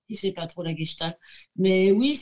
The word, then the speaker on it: Gestalt: Ah, attends, es en train de dire que dans le Gesalt, je le prononce bien. Gestalt.